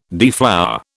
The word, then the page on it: deflower: British English